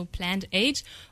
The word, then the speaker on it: Plant Age: Ja, wir haben Plant Age sogar gegründet und Plant Age steht für Pflanzenzeitalter, also Plant Age, und gleichzeitig auf dem deutschen Plantage ausgesprochen steht dafür eine landwirtschaftlich genutzte Fläche.